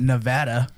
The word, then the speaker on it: Nevada: I mean, I don't claim to be the smartest man on this planet, but I know how to say Good. Nevada. – Yeah, me too. I just said it like four times, Nevada.